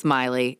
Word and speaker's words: Meili: Okay, so on the night of April 19th, 1989, Trisha Miley went out for her regular run in Central Park. I think it's Miley. It's spelled M-E-I-L-I. – That's how I would say it.